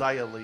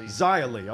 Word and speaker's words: Xia Li: Is that Xia Li? Is that how she pronounces it? – Xia Li. – Xia Li.